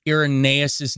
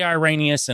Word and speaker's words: Irenaeus: I was seeing Irenaeus. It's Irenaeus, which is funny because I pronounced it right before on other shows.